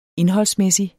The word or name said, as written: indholdsmæssig